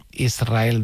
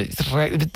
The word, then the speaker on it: Israel: Porque Israel creo que le sonaba muy grande, ¿no? Israel, ¿no? – Y la S con la R es difícil de pronunciar. La gente dice Israel, que suene la S.